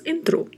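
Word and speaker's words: entró: Não, pronunciamos entrou. Escreve-se apenas até a letra O e vamos usar um acento agudo na letra O. Então, por mais que exista esse acento agudo, a pronúncia permanece sendo fechada. Nós vamos falar entrou, entrou.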